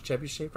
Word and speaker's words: trebuchet: I did pronounce trebuchet right. – You did.